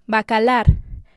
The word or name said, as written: Bacalar